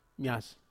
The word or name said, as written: mas